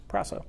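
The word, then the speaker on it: proso (πράσσω): So you got pi, rho, alpha, sigma, sigma, omega. Pronounced proso.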